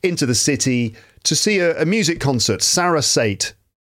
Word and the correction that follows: Sarasate: I don't know if I'm pronouncing it properly. Yeah, I just checked and I got that wrong. It should be pronounced Sarasate or Sarasate.